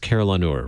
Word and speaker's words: carillonneur: It's pronounced carillonneur, right? – That's correct.